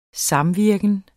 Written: samvirken

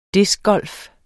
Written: DISC

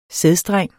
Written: sædstreng

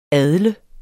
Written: adle

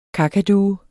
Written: kakadue

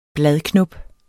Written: bladknop

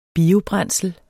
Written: biobrændsel

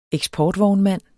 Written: eksportvognmand

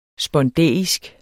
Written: spondæisk

